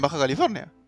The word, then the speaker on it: Baja California: Baja, Baja California, sí. ¿Qué dije? Es un, lugar raro. – lo dije bien, Baja California. – Sí, sí, sí, está bien.